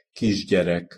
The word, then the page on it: kisgyerek: Hungarian